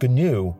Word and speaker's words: GNU: I'm sure a few people are going to tell me that it's pronounced new with no G, but people who used it extensively back in the day have assured me it is pronounced GNU, so that's what I'm going to use.